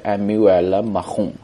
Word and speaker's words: Emmanuel Macron: 那么这个获胜之后呢 ,Emmanuel Macron, 这是他的法语名字的发音。当然我 ,my French is very awful, I just asked some of my French friend and they told me how to pronounce the word. 所以我读的是 Emmanuel Macron, OK?